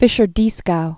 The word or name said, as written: Fischer-Dieskau